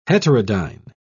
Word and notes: heterodyne: British English

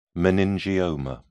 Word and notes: meningioma: British English

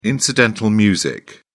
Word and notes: incidental music: British English

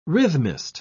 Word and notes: rhythmist: British English

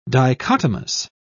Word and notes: dichotomous: British English

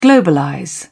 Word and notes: globalize: British English

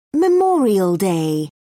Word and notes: memorial day: British English

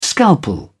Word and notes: scalpel: British English

